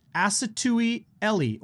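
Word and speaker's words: Asotui Eli: A lot of people out there saying his name wrong. It's Asatui Eli, or Tui, as he goes, for short for one of his first names.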